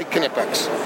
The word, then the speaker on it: Knipex: Knipex Invicta, but Knipex, you pronounce the K. So it's always you say Knipex.